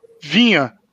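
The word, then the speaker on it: Viña: Mas temos Gustavo Gomes servindo a seleção paraguaia, Everton servindo a seleção brasileira, Vinha, Vinha, né, Ed? Falei certo? Pronunciei certo? – Falou, Não, falou.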